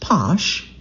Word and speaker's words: Pox: One of the first commercial bottlings of Pox, I'm seeing it written P-O-X, but they pronounce it Posh,